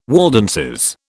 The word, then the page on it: Waldenses: British English